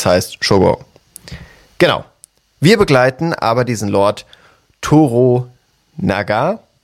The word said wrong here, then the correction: Toranaga: Toranaga, Toranaga, genau. So ist es ausgesprochen, richtig.